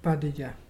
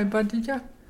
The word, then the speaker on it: Barraja: That is spelled B-A-R-R-A-J-A, but pronounced Badeja. Can you say Badeja?